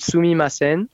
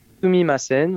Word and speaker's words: Sumimasen: vous leur dites Sumimasen. – Sumimasen. Et euh, Sumimasen, voilà, donc euh, quatre syllabes, hein, Sumimasen.